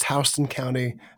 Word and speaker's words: Houston: You pronounced Houston County the right way.